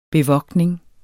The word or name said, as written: bevogtning